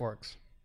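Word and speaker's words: Orruk: Still a silly name, but it's still pronounced orcs. I, want to say or orc.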